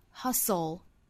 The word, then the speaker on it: hustle: hustle 注意舌侧音的舌头的摆位舌尖距底上齿音当然这里面的这个舌侧音呢还要跟前面的 s 去组合 hustle 我们注意这个单词里是含有一个字母 t 的但是字母 t 是不发音的哈我们在发的时候是